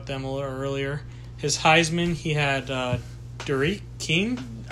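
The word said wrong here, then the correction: D'Eriq: I think it's pronounced D. Eric.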